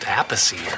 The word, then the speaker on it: Appice: Hey, wait, I thought it was like pronounced Appice or something like that. – Technically, if it's the Italian pronunciation, it's Appice.